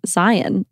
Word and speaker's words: Zion: So we went to Bryce Canyon, Zion National Park, which actually is pronounced Zion, like lion.